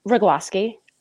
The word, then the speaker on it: Kruglowski: It's Kruglowski. I don't know. Everybody tries to add in like an Owski, because a lot of, I guess, the ski names end in that.